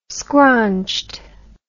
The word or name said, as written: scraunched